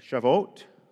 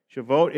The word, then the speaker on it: Shavuot: Shavuot, right? Shavuot is S-H-A-V-U-O-T, is a name for this festival that marked not only this harvest time, but it also marked 50 days after Passover.